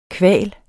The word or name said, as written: kval